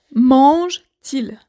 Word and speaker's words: mange-t-il: Mais donc, je répète, mange-t-il. Normalement, il n'y a pas de T mais pour la prononciation, on a rajouté un T.